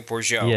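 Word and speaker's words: Bourgeault: Yeah, B-O-U-R-G... – I think it's Cynthia Bourgeau.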